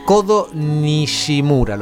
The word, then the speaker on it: Kodo Nishimura: ¿Quién es Kodo Nishimura, ¿lo pronuncié bien? – Sí, está muy bien.